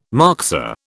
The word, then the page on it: moxa: British English